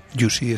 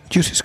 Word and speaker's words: Jussy: Juicy se escribe con J-U-S-S-Y.